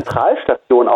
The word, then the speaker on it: Central Station: September um 20 Uhr in der Central Station in Darmstadt. Gut, das wären jetzt meine Fragen. – Ich glaube, es ist Zentralstation ausgesprochen.